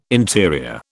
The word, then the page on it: interior: British English